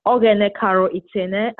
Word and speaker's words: Oghenekaro Itene: It's Oghenekaro Itene,